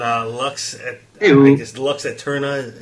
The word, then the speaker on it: Lux Aeterna: When Lux Aeterna is how you pronounce the song.